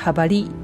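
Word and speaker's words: jabalí: で、同様に、前回やったハバリなんていうイノシシっていう単語なんですけれども、これも最後のリを強く発音させたいので最後の I の上にアクセントをつけます。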